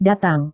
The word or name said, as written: datang